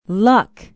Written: luck

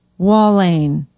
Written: walhain

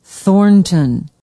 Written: thornton

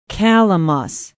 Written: calamus

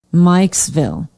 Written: Mikesville

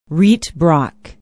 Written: Rietbrock

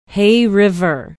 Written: hay river

